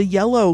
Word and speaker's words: Yello: Carlos Peron, you know, the yellow guy. Y-E-L-L-O, rhymes with jello.